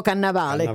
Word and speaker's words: Cannavale: Diciamo Cannavale oppure diciamo Cannavale? Diciamo Cannavale.